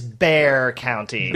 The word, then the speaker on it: Bexar: So how would you guys pronounce B-E-X-A-R County? – Bexar. – Bexar. – You'd be wrong because it is Bear County.